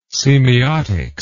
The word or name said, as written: semeiotics